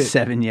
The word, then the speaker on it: Sevigny: Sevigny.